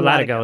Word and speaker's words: latigo: The cinch features newly designed buckles made from solid stainless steel and shaped to keep the latigo securely in place. Is it latigo? – No, latigo is correct. Yes.